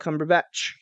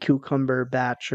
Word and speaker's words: Cumberbatch: Doctor Strange, Benedict Cumberbatch. Sorry, I was thinking about a funny way that Robert pronounced it the other day. He said cucumber batch or something like that.